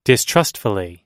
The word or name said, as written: distrustfully